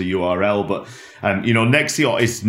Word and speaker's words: Nexxiot: So tell me about the path that led you to become the co-founder of Nexiot.